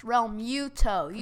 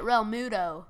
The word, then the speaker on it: Realmuto: You don't even know how to say his name. It's Realmuto. You say it Realmuto.